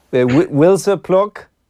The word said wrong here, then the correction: Wisła Płock: Yeah, to be fair, that's how I would have said it when I first came over. So it's Wisła Płock.